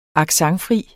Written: accentfri

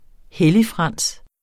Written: helligfrans

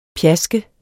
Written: pjask

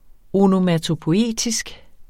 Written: ONOMATOPOIETISK

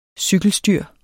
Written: cykelstyr

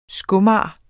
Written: skomager

